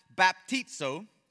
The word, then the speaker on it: baptizo: But if you notice, the letters look pretty close, but the original Greek word would have been pronounced baptizo.